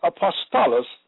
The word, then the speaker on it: apostolos: The word apostle in the Greek is apostolos, which I told you that earlier, pronounced apostolos, which means a delegate,